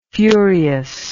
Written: furiousness

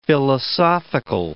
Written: philosophical

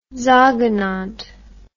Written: sogenannt

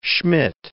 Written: Schmidt

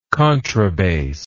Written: contrabass

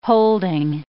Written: holding

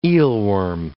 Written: eelworm

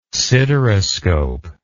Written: sideroscope

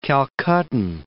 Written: Calcuttan

